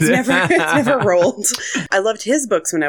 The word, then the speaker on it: Roald: I always think it's Ronald, and then I'm like, no, there's a letter missing. – No, it's not Ronald. There is no N.